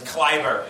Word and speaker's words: Kleiber: If you're white, it's Aaron Kleber, and if you're German, it's fucking... – It's Kleiber.